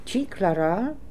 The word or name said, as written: Chiklaral